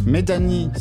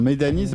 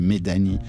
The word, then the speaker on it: Medani: Medani sur Radio Nova, enfin Medani, c'est parce qu'on a checké comment ça se prononce, ça vous a évité un Medan à l'antenne qui aurait été moins joli, donc parce que ça s'écrit à la française, tu dirais plutôt Medan pour être exact, mais voilà, à l'anglais ça fait Medani.